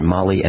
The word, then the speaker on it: Mahle: Bought some Mahle gaskets, M-A-H-L-E. It looks like Mahle. If you were to see the word, you'd pronounce it Mahle, M-A-H-L-E. It's pronounced Mahle.